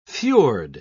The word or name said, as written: fjord